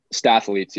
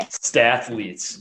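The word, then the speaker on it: Statletes: So we've dove into a lot of the analytics here, and I'm curious with Statleets. Is that how it's pronounced? – Statleets. Yeah. – Statleets. It's like mathletes, yeah.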